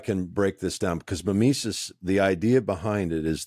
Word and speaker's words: mimesis: Mimesis, is how would you say it? – I used to say mimesis, but it's mimesis. Just okay. – Wherever we put the accent.